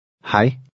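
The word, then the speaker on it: hej: Hi. Hi. – The EJ combination sounds like I.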